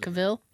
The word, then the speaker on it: Cavill: Cable. How do you pronounce it? – Cavill? – Cavill.